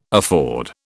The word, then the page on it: afford: British English